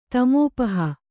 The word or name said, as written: ತಮೋಪಹ